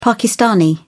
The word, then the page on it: pakistani: British English